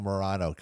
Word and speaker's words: Morano: That's the one that Reed Morano directed. – Yes. That's what I was saying. Reed Moreno. – Oh, Moreno, Morano. Gosh, darn it. So I guess I didn't say her name right.